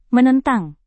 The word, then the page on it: menentang: Indonesian